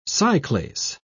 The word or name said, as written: cyclase